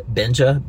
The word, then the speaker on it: Benja: The little at symbol, you know, at Benja, B-E-N-J-A, and then well done. W-E-L-L-D-O-N-E, all together, like, hey, good job.